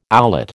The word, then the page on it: owlet: American English